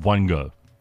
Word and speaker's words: Wango: The dating app is called Wongo, as in, want to go for coffee? – Ah, nice. – No, you want, because you spell it Wango, but you pronounce it Wongo.